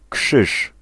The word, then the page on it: kruis: [kɾʌjs]